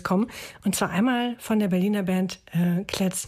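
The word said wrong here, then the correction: Klez.e: Jetzt wollte ich dich vorher noch fragen. – Klesee. – Klesee, genau. Ich habe schon wieder vergessen, wie sie ausgesprochen werden.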